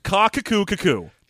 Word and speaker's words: Qaqquqqu: So, this is spelled Q-A-Q-Q-U-Q-Q-U. And yeah. we spent some time while getting ready to do this episode discussing the pronunciation, and we settled on Lord Kakukku.